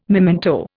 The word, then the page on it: memento: Indian